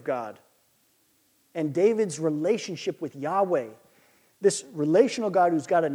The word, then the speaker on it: Yahweh: So, there truly is no consensus at this time on how it's pronounced, the name of God. But most have landed on Yahweh, Yahweh.